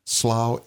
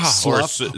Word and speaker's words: Slough: Yeah, Slough, or something like that. – Yeah. – It rhymes with plow, Slough.